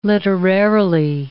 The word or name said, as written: literarily